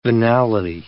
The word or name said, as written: venality